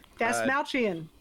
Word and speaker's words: Dastmalchian: Dastmalchian.